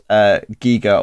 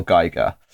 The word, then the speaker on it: Giger: and there is a big discussion about whether his name should be pronounced Giga or Geiger. And our guest today, Miroslava Hartmann, who will give her biography in a minute, schools us and tells us the definitive answer. Miro, what is the correct pronunciation? – Well, the correct pronunciation is most definitely Giga.